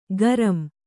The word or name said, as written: ಗರಮ್